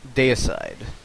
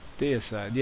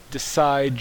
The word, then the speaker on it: Deicide: You know, you get decide judgment. – I guarantee that there will be people at FNM who are mispronouncing that who will drive me crazy and calling it Decide.